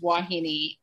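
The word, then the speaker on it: Vauhini: So it is pronounced like a W. It's pronounced Wahini.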